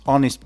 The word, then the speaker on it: Honest: So, the pronunciation is honest, not honest. There is no H. Well, there is a H in the spelling, but in the pronunciation, no.